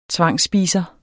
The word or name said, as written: tvangsspiser